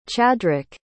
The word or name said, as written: Chadrick